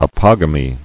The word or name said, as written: Apogamic